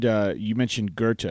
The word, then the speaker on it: Goethe: And you mentioned Goethe in there, correct? – Yes, sir, I did.